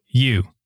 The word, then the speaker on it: ewes: I want you to pronounce this because the way I would pronounce it, it's probably not right, but it's E-W-E-S. I would call them ewes. – It's you.